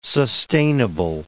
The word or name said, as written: sustainable